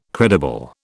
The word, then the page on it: credible: British English